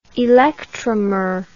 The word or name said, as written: electromerism